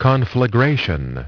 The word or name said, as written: conflagration